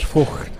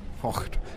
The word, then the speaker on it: Voigt: I was just going to tee up a few minutes of my chat with Jens Voigt, or Jens Voigt, as we are supposed to say, because that's how the Germans pronounce his surname. Pronunciation police, is that okay? – Yeah, Voigt.